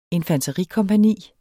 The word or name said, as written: infanterikompagni